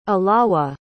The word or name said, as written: alawa